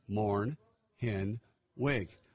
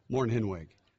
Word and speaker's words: Mornhinweg: It's very simple. It's a German name. It's three syllables. M-O-R-N, Morn. H-I-N, pronounced H-E-N, Hen. W-E-G, pronounced W-I-G. Morn, Hen, Wig. Morn, Hen, Wig.